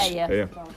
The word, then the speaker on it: Ayr: A-Y-R, Ayr. – Is that how they pronounce it, in Ayr? – Is it? Yes. Ayer. Pronounced it right.